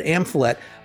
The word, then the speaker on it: Amphlett: But Amphette. I'm probably saying her name wrong. See, this is what I hate. I cannot speak English, but Amphlett, impeccable vocals, the band had driving guitars, solid active rhythm section.